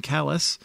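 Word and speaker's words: Calais: But the first thing is, when we say Down East, and talking about the communities of Baileyville and Callis, I think Lisa wanted to make sure I pronounced that correctly.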